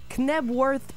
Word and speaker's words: Knebworth: Le spectacle avait lieu à Knebworth, en Angleterre. Ah, quelle prononciation tellement belle qu'on va y faire reprendre, s'il vous plaît. – Knebworth Park, en Angleterre.